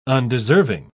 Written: undeserving

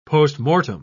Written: postmortem

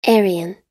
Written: Aryan

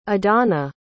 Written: adana

